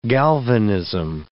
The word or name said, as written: galvanism